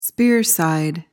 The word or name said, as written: spear side